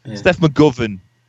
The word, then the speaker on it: Steph McGovern: Is it Steph McGovern? Have I got her name right? – Yeah, it's Steph, yeah. Steph McGovern.